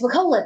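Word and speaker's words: Wirkola: Oh, yes, Wakola. That's how you say it.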